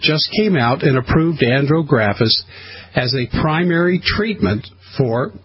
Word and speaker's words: Andrographis: Andrographis, is that right? – Yes.